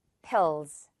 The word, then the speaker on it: pills: Pills. It's not pills. Peel 뭐 이렇게 발음하면 안 된다는 거. Pills.